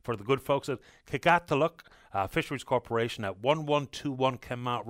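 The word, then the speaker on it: Qikiqtaaluk: I said I couldn't pronounce the first name of the Fisheries Corporation. I couldn't until I just heard from the GM, Joel Hickey. It's Kikatiluk. Kikatiluk Fisheries, and they're at 1121 Kenmount Road in Paradise, just past Robin's Donuts.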